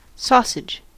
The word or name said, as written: sausage